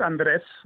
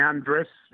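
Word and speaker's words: Andrés: Well, in Spanish, it's Andres. – Right. – But I've been, obviously, everyone calls me Andres. It's totally accepted. It has an accent on the E.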